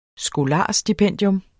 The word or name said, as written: skolarstipendium